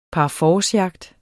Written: PARFORCEJAGT

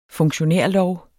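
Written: funktionærlov

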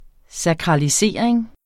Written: sakralisering

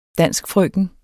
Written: danskfrøken

